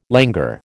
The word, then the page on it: langur: American English